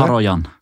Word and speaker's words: Haroyan: Haroyan.